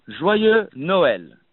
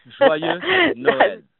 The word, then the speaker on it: Joyeux Noël: So, alors, on dit Joyeux Noël. Joyeux Noël. Easy, guys. Come on. Come on. You can say too. How long you live in France? – Joyeux Noël.